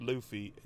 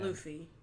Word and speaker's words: Luffy: It's pronounced Luffy. And Luffy.